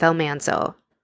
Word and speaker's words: Almanzo: And I want to tell you more about the real stories behind the banks of Plum Creek, the long winter, and even Laura's life with Almanzo. Yeah, that's how it's pronounced.